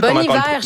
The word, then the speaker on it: Bon Iver: Il y a Justin Vernon de Bon Iver. Je le prononce-tu bien? Je me rappelle plus Bon Iver, je me rappelais.